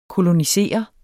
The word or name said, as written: kolonisere